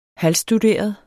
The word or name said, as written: halvstuderet